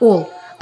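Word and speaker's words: all: «Ал» читается как «ол», означает «все».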